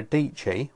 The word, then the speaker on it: Deechy: And in fact, this was brought to my attention by Sarah Deechy, rhymes with peachy,